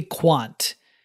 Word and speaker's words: Kvant: But none do I butcher as much as I do one that's spelled K-V-A-N-T. Because I forgot, while recording the episode, that in Russian, V is essentially like a W-U type sound, like vodka is vodka. So through the entire episode, I pronounce it as kvant, when it should be quant, which makes way more sense because it does reference quantum.